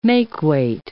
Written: makeweight

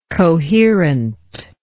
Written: coherently

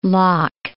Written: Locke

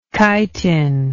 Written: chitinoid

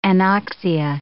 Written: anoxia

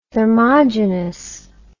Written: thermogenous

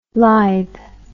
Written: lythe

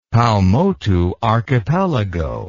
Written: Paumotu Archipelago

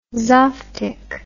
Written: zophtic

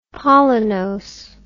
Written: pollinose

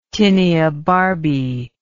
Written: tinea barbae

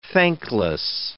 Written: thankless

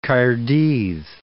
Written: Caerdydd

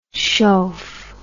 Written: chauff

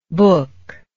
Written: bukh

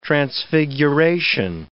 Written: transfiguration

